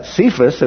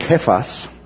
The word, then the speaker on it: Cephas: And then Cephas, as we usually pronounce it, was actually from the Aramaic Kephas, and then son of John.